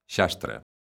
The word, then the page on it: xastre: lingua galega